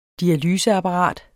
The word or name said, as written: dialyseapparat